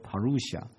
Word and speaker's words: parousia: 新约圣经是用古希腊文所写的，古希腊文里面有一个字被用来讲基督的再来，在英文里面读作 parousia，希腊文大概读作 parousia。